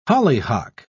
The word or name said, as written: hollyhock